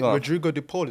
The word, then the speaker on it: Rodrigo De Paul: And it's, is it Rodrigo De Paul? Is that how to say his name? – Yeah.